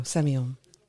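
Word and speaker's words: Sémillon: Semillon, yeah. – Semillon. – It's very simple. Semillon. It sounds weird.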